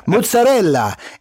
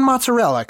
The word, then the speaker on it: mozzarella: And by the way, anyone from Italy, from the country of Italy, would never, ever in a million years say less syllables when the possibility is to say more syllables. It's mozzarella, if it's anything at all. But it's fucking mozzarella.